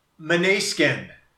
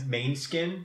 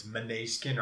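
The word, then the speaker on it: Måneskin: Yeah, and it looks like it's main skin, mm-hmm. – but I just right before I got here, I looked it up and it's Meniskin or Manoskin.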